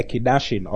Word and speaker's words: Kiddushin: K I D D A S H I N the Kiddushin or the sometimes it's spelled Kid, Kiddushin, K I D D U S H I N, the Kiddushin, or the betrothal.